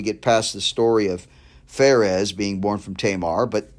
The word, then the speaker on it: Perez: It's pronounced Perez here in verse 3.